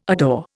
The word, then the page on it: adore: Indian